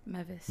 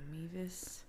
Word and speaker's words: Mevis: Mevis. – Mevis. – Not Mevis. – No, not, please don't call me Mevis.